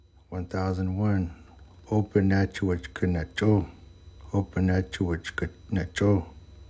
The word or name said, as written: sali7 open nacho7wech qe necho7